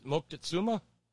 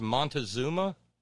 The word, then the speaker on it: Moctezuma: Moctezuma. We mispronounce his name as Montezuma.